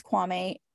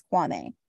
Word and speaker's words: Kwame: And there was a lot of drama with Kwame. So the correct pronunciation, the Ghanaian pronunciation of his name is Kwame. – Okay. – But for some reason, him and the entire cast insisted that it was Kwame.